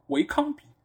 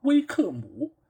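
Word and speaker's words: Wycombe: 嗯，同样的这个问题，其实呃还有就是呃有一支英甲球队啊，叫维康比。维康比其实。它的问题出在就是最后的鼻翼，它其实应该是不发音的，应该是它最后这两个字应该是叫威克姆，对吧？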